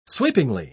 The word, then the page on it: sweepingly: British English